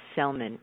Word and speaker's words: Sellman: It's Dr. Dr. Cheryl, S-H-E-R-R-I-L-L, Selman, S-E-L-L-M-A-N. So lots of R's, lots of L's in there. S-H-E-R-R-I-L-L-S-E-L-L-M-A-N, Dr. Cheryl Selman.